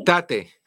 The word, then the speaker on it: Tate: Tay. ¿Cómo se escribe? Eh, T. – Tate. – Ajá.